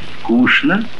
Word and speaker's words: скучно: В живом же языке эта группа ЧН перешла в ШН. Конечно, скучно, молочное, пустяшный.